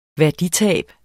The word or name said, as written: værditab